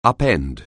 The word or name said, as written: upend